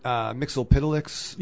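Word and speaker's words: Mxyzptlk: I always thought it was Mixelplick from Super Friends. – Yeah. – And then they later started pronouncing it Mixelpitlix.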